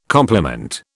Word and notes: complement: British English

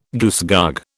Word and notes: goosegog: British English

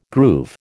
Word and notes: groove: American English

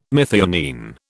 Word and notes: methionine: British English

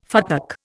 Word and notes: futtock: Indian

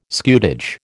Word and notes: scutage: American English

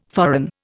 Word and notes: foreign: Indian

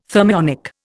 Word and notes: thermionic: Indian

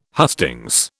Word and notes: hustings: British English

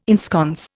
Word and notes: ensconce: Indian

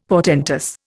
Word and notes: portentous: Indian

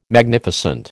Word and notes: Magnificent: American English

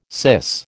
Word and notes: syce: American English

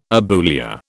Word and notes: abulia: British English

